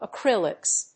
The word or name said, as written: acrylics